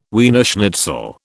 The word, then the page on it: Wiener schnitzel: British English